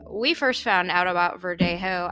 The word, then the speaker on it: Verdejo: It's pronounced Verdejo.